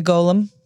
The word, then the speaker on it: golem: Like a golem. – Yeah. – Like was that how it's pronounced? – It is.